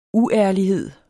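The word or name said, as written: UÆRLIGHED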